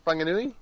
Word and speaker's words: Whanganui: Yeah, Whanganui. – Oh, is that how you say it? – Yeah. – Holy shit, why is it with a W? – So a lot of WHs are like Fs.